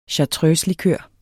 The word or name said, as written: chartreuselikør